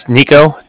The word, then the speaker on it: Niko: It's Nico, spelled N-I-K-O. Mm. And then the Bank of Cardiff is spelled C-A-R-D-I-F-F, as in Frank.